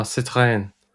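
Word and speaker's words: Citroën: How do you say Citroen? – Oh, Citroen.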